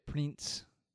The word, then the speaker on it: Prinz: The next one is from Germany, Bridget Prinz. I don't know. Let me talk to my German comrade over here. How would you pronounce P-R-I-N-Z? Prinz. – Prinz. – Prinz. Prinz. Prinz. And then the first name is B-I-R-G-I-T.